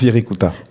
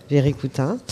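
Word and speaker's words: Wirikuta: La terre où est né le soleil, c'est ce territoire désertique du centre du Mexique où se trouve Viricuta, ou je ne sais pas comment on le prononce. – Virikuta. – Virikuta.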